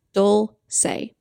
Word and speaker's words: Dulce: Two syllables, Dulce. Three syllables, Ma-C-L.